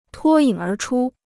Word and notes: 脱颖而出: tuō yǐng ér chū

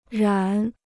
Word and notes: 染: rǎn

